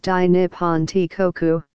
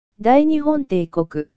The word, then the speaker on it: Dai Nippon Teikoku: From the Meiji Restoration until the end of World War II, the full title of Japan was Dai Nippon Teikoku. – Dai Nihon Teikoku.